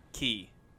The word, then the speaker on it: quay: Q-U-A-Y. – Q-U-A-Y. – How do you pronounce that word? – Key. – B-E-R-K-E-L-E-Y. – B-E-R-K-E-L-E-Y?